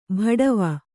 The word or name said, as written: ಬಾಡವ